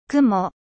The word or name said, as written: nuage